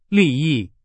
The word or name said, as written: 利益